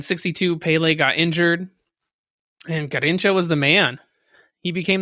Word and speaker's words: Garrincha: That is Garrincha. However you pronounce it. – Yeah, I think you got it right.